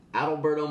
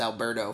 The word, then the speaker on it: Adalberto: Good pronunciation on his name, because I'm going to be honest, I thought his name was Alberto for the past 2 years. – There is a D-A-L in there.